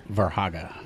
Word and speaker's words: Verhage: Verhaga.